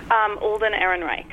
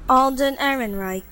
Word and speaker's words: Alden Ehrenreich: How do you pronounce his name? – Alden Ehrenreich. – Right, we've YouTubed it. – Alden Ehrenreich. – Yeah, you're right.